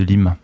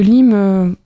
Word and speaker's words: Lyme: Est-ce que vous pouvez nous expliquer un petit peu ce que c'est la maladie de Lyme? – Alors la maladie de Lyme, on prononce plutôt maladie de Lyme. – Ah, merci beaucoup. – Parce qu'en fait, elle a été découverte aux États-Unis.